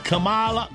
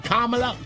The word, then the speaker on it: Kamala: I've heard Kamala and Kamala, so I'm not sure which it is. – Yeah, well, anyway, I think it's pronounced like the punctuation mark. – That's comma.